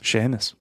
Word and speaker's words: Seamus: Do you call him Seamus or do you call him Seamus? – Seamus. – Okay, good. Because it's spelled Seamus, you know.